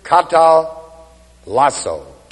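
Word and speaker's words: katallassō: The word reconciled is the Greek word katalasso, K-A-T-A-L-L-A-S-S, long O.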